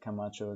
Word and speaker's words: Camacho: Like Camacho is in C A M A C H O, the way my name is spelled.